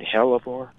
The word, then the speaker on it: hellebore: That's a wonderful I got the pronunciation right, hellebore? – Yes. – Yeah. – Uh-huh. – Uh-huh. Okay. H-E-L-L-A-B-O-R-E.